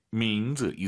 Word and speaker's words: 名字: 이름, 민즈, 즈, 쌍즈 아닙니다. – 名字。 – 민즈, 이성경성, 아하 생각하시면서 민즈 이렇게 발음하시고요.